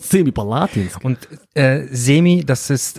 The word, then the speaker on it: Semipalatinsk: Semipalatinsk. – Korrekt. Semi-Palatinsk, also nicht ganz, sondern nur semi, oder wie? Ja, das ist, ähm, also die Betonungen liegen schon mal nicht richtig.